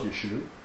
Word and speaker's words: Yeshua: And his name was Yeshua. There was no pronounced A at the end of his name in the Galilean extract.